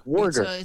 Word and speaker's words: Warger: Warger, soft G? – Hard G, Warger. – It's a hard G.